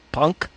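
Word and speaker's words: Punque: His name is Punk. – Closely it's, associated with Bastard. – It's spelled Q-U-E. P-U-N-Q-U-E.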